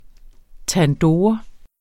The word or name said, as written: TANDOOR